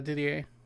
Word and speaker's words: Didier: Samwise Didier, is that how you say it? – Yeah, Didier.